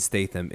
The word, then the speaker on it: Statham: Real quick, I want to hear you guys do your best impression of Jason Statham introducing himself.